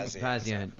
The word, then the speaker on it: Pazienza: Vinny Pazienza.